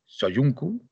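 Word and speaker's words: Söyüncü: dos fichajes Soyuncu, que creo que se pronuncia así, si Felipe no me corrige posteriormente cuando ya le dé paso. No, no te corrijo, lo estás, diciendo muy bien.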